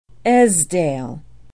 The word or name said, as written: Esdaile